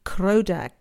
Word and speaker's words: Krodha: Padman, P-A-D-M-A-N. Tatro, T-A-T-R-O. The next one took me a while to get. Vajra, V-A-J-R-A. Krodha, K-R-O-D-H-A. Haya Griva, we've done. Hulu Hulu, exactly like it sounds, H-U-L-U. Hulu Hulu. Hum, H-U-M. Te, P-H-E-T. So you've got Om, Hri, Padman, Tatro, Vajra, Krodha, Haya Griva, Hulu, Hulu, Hum, Te.